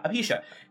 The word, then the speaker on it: alopecia: I didn't know that his wife had alopecia. That's did I say that right? Yeah, alopecia.